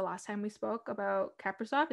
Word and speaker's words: Kaprizov: Some people say Kaprizov. I think I was watching highlights because I wanted to make sure and it's Kaprizov. – Kaprizov.